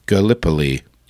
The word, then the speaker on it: Gallipoli: Gallipoli. Okay, Gallipoli. Interesting. I thought it was Gallipoli. Gallipoli. Gallipoli. But that makes sense. Gallipoli. Even though the way they pronounce it sounds like it should have two P's in the middle, but whatever.